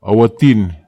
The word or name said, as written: ahu atin